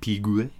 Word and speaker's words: Piguet: Is that an Audemars Piguet? – Yeah, that? I never know how to pronounce it. Piguet? – Piguet.